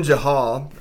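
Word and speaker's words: Jael: Then Jahal, Heber's wife, I think that's how you pronounce it, right? – Yep.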